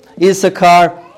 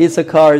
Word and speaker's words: Issachar: But this son, the ninth one, Issachar, it sounds like the word wages, Sakhar.